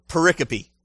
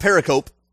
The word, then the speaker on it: pericope: Pericope. It's spelled P-E-R-I, peri, and then C-O-P-E, cope. It's not pericope, it's pericope.